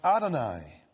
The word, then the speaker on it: Adonai: And then the second word, Lord, in the Hebrew it is pronounced Adonai.